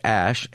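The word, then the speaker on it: Æ: The letter X, and then the A-E is pronounced ash, and then A-12 was my contribution.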